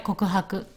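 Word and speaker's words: kokuhaku (告白): Can you pronounce again? Kokuhaku. – That's right, kokuhaku.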